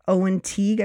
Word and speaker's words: Teague: Owen Teague, I believe it's pronounced.